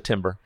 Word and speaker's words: timbre: The timber. – The timber, because it's spelled not at all how it sounds.